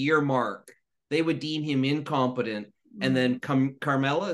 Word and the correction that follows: Kamala: is that how you say it? The vice president would take over being president Kamala. – For the rest of the term. – Oh yeah, Kamala, the first.